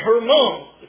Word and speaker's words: Hermon: Tabor, and that's the correct way to pronounce that, I learned in Israel, and Hermon, which is the way the Israelis pronounce it.